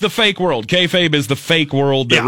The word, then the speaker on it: kayfabe: Kayfabe? – That one.